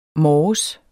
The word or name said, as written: morges